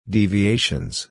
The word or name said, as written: deviations